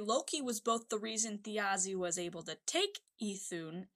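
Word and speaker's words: Þjazi: Okay, it's a thorn. J-A-Z-I. – There we go. Pronounced Thiazi.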